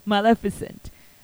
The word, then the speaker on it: Maleficent: And I actually, when I was preparing the show for today, I saw a very close resemblance with Maleficent, that's how you pronounce it, Maleficent.